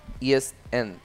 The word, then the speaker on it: East End: East End.